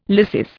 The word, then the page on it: lysis: Indian